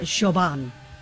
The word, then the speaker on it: Siobhan: Pronounce this one. S-I-O-B-H-A-N. S-I-O-B-H-A-N. Yes. – Following this rule that I figured out, Shoban. – You know what? I'm going to give that to you.